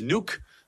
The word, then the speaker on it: Nuuk: That is how you say it, not Nuuk. It is Nuuk.